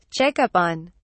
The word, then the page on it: check up on: British English